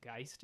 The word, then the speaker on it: Geist: And her whole record that came out last year, I believe it's pronounced Geist. It's G-E-I-S-T.